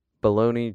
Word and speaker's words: baloney: Baloney, just like pronouncing Bologna, baloney.